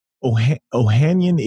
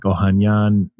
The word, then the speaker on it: Ohanian: So how do you pronounce it? Give it to me. – Ohanian is what I say. I feel like there's a much more Armenian way of doing it, which is like Ohanian, but I just say Ohanian, Alexis Ohanian.